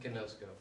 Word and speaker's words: ginosko: That's that word, ginosko. And I know I'm not saying it right, but that's the word that Paul used to describe his relationship with Christ. Are you laughing at me because I'm saying it wrong? What is it? – Gnosko. – Gnosko.